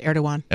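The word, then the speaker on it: Erdoğan: I think it's pronounced Erdogan. – Erdogan? Okay. Yeah. Doesn't look like it. I don't know where they got the W from.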